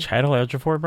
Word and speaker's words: Chiwetel Ejiofor: While they're trying to do that, stars Charlize Theron as Andy, Kiki Lane as Niall, and the name I can never pronounce, which is, I think, is Chiwetel Ejiofor as Copley. – Chiwetel Ejiofor, bro? Is that what you're trying to say? – Yes, that's what I was trying to say.